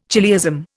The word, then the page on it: chiliasm: Indian